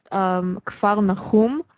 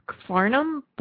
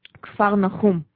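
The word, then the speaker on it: Kfar Nachum (כפר נחום): Well, actually, there's a band that Matt Heafy from Trivium was in, and I always thought it was pronounced Kfarnum, but in Hebrew... Kfarnum, whatever. In Hebrew, it's pronounced the way that I pronounced it before.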